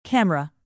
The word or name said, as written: camera